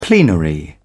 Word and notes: plenary: British English

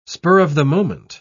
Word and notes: spur-of-the-moment: British English

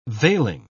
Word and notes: veiling: British English

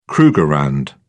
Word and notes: krugerrand: British English

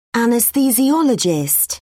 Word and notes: anesthesiologist: British English

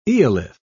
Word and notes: eolith: British English

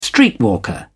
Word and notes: streetwalker: British English

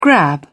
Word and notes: grab: British English

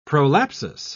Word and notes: prolapsus: British English

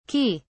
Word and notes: ki: British English

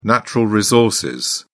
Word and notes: natural resources: British English

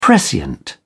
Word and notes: prescient: British English

